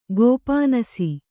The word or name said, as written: ಗೋಪಾನಸಿ